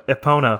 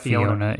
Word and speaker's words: Epona: Epona from Zelda. I also it was Fiona. – Yeah, for some weird reason. – I don't know why I added an extra syllable.